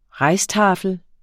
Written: rijsttafel

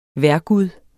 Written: vejrgud

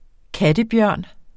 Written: Ailurus fulgens

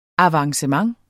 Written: avancement

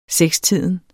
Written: sekstiden

